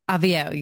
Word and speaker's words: Avvio: In March 2020, Sage began producing virtual events and launched Avvio. Did I say that right? Avvio. – Avvio, you got it.